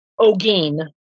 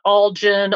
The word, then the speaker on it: Olguin: So when I went in with the maiden name of, it's Olguin, O-L-G-U-I-N, Olguin. I'm Hispanic, but I don't look it. So when I got there and people saw my name, I thought at first they kept pronouncing it Olgin, Olgun, Sheila knows, she's seen my maiden name.